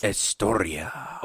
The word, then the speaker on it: Estoria: It's called Estoria, but it's pronounced as a passionate Spanish lover. – Yes. – Estoria.